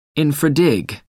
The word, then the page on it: infra dig: American English